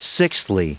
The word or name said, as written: sixthly